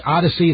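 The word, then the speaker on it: Audacy: it's pronounced Odyssey. It's not spelled Odyssey. It looks like Audacity, but it's not that either.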